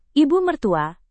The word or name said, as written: ibu mertua